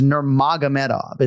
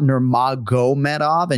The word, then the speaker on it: Nurmagomedov: It's spelled M-A-G-O-M-E-D, but it's not Magomed. It's Magomed, right? – Right. – And so it is Nurmagomedov. It's not Nurmagomedov. And you'll hear Umar say exactly that right here, right now. – My name's Umar Nurmagomedov.